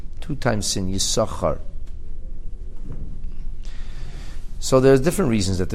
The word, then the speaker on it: Yisachar: And some people mistakenly say Yisachar, but they're not supposed to pronounce it Yisachar. If you read the Torah pronouncing it Yisachar, that's not appropriate. The name is Yisachar.